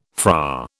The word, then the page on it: Fra: British English